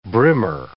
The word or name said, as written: brimmer